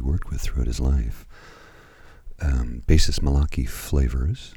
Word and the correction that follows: Favors: sorry, Malachi Favors. Wow. I just butchered his name and I feel terrible about that magnificent drummer Elvin Jones, saxophonist Steve Lacy, and the one and only irreplaceable Ray Charles.